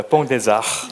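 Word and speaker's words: Pont des Arts: 그리고 프랑스 파리에 뽕데자흐라는, 재수없진 않나요? 네. 정확한 발음입니다. 뽕데자.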